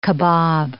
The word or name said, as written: kabob